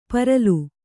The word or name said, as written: ಪರಲು